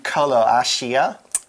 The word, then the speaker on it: Croatia: I have been to Croatia.